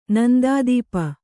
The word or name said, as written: ನಂದಾದೀಪ